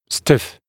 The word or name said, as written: stiff